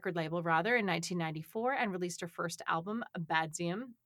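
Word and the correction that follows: Baduizm: I think it's Badduism. – Badduism? Oh, it is. That is exactly what it is.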